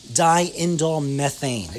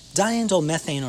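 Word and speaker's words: diindolylmethane: Now, some additional things for reducing the aging process would include a chemical that's found in cruciferous vegetables, and it's abbreviated DIM, D-I-M, or I always mispronounce this, even myself after all these years, is diindolmethane. I got to say it slow, not three times fast. So diindolmethane,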